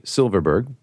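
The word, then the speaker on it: Silfverberg: But as a Swede, I have to point out that the F in Silverberg is silent. So it should just be Silverberg, just the V, which basically means Silver Mountain in Swedish.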